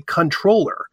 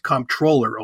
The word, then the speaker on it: comptroller: It's really controller. But when these pedants in the 1500s and 1600s decided to go backwards to the origins of the word, they put the P back in. And controller, like in a business thing, is not pronounced comptroller, although it a lot of times is spelled C-O-M-P.